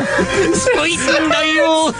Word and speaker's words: Spuyten Duyvil: Spuyten Duyvil.